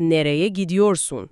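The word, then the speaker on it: Nereye gidiyorsun: میشه نره گیدیورسون، که تلفظش هم هست نره گیدیورسون.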